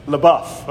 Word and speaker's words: LaBeouf: It makes me think of how a few years ago there was the actor Shia LaBeouf. Did I pronounce that right? LaBeouf. Okay, I always see that name and I can never.